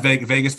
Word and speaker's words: Vegas: It is Vegas Ferguson.